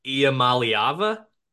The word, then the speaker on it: Iamaleava: I'm going to try to pronounce the last name, Nico Iamaliava. – That was perfect.